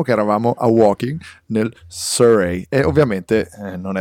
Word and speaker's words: Surrey: Surrey. Surrey. Surrey.